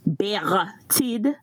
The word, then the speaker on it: Bertied: This is Bertied, and I'm going to pronounce my name like we do from the island. Bertied is the name, so that's the pronunciation.